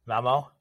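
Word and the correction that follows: Malmö: Malmo, Malmo, yes. um, which are kind of the three major hubs.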